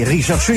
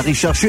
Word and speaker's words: RCHRDSCHU: musica allegra per le vostre orecchie in questo weekend firmato Rete 8 Network e Rishashu, il vostro nuovo amico in musica con Vivitali. Ricordate di poter anche scrivere a Rete 8 Network e a Rishashu e RCHRDSCHU,